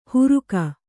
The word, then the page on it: ಹುರುಕ: huruka